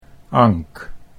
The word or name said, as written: Ank